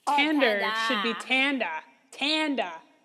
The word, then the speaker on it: Tander: Wait, because Tanda or Tander? – Tander, T-A-N-D-E-R. Okay. – You'd think now, I would be the one pronouncing that wrong, but no. Tanda. Well, Tanda. Yeah. Tanda. – I'm just saying, with a Boston accent.